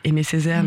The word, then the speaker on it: AIMEZCZR: C'est un jeu de mots donc avec Aimé Césaire, mais ça s'écrit A-I-M-E-Z-C-Z-R de musique, parce qu'on adore la créativité et les jeux de mots ici.